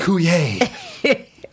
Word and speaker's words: Coulier: If it's a 90s reference, I'm going to know that is Dave Coulier. That's right. There's an R on the end there, but you don't pronounce it.